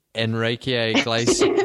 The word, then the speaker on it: Enrique Iglesias: Apart from the Enrique Iglesias, how do I pronounce his name? – No, you're doing well.